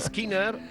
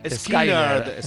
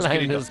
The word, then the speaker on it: Skynyrd: Y en vez de Skinner, Skynyrd. Skynyrd, ¿no? – Digo Skynyrd. Skynyrd.